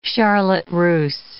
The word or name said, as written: charlotte russe